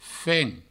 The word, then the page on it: fen: Provençal